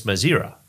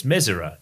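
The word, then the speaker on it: Mezera: When I spoke to Thomas Mesera, I've always said Thomas Mazira because that's what everyone says. Thomas doesn't really care what you call him, but it's Mesera is the way he pronounces it.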